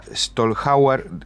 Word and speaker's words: Schlotthauer: Bueno, estamos en contacto con Argentina, con la ex diputada Mónica Stolhauer. Decime si está bien pronunciado así. – Sí, no te hagas problema.